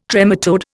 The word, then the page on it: trematode: Indian